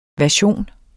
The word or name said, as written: version